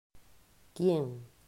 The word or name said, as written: Quien